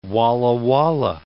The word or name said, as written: Walla Walla